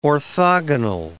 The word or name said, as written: orthogonal